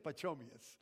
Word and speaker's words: Pachomius: I probably pronounced it wrong. Sorry, Pacho. Pacho. I think, you know what? I think it's Pachomius. I think it's Pachomius. You know, I was trying to pronounce, how would I say this word? Pachomius.